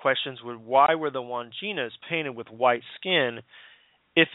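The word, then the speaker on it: Wandjinas: But one of the most intriguing and perplexing legends is the Australian Aboriginal people of the that talk about the Wanginas.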